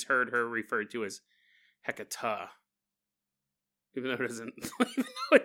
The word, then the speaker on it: Hecate: Hecata is how I heard it previously used. Like the E sound in enough.